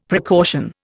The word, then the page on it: precaution: Indian